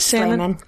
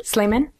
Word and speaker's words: Slamon: Yeah, the L and the A are swapped. So people say Salmon all the time. – But it is Slamon. Spelled like salmon? S-A-L? – It's S-L-A.